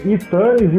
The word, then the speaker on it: iTunes: É iTunes, iTunes, iTunes iTunes. iTunes. Então tá aí, peguei a dica aqui do, do, do universitário que já me garantiu que é iTunes. E eu falava iTunes.